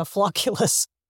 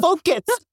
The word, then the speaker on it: Focus: First, I keep butchering the name on accident and calling it the Floculus. It's like, Floculus? – It's like, no, that. Focus. – Focus. That's amazing.